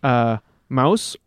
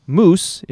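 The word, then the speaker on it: Mousse: And it also introduces Mouse, or if you prefer the European statement, or Moose, if you like a more American pronunciation, the weapon master who is desperately in love with Shampoo, to this already raucous group of characters.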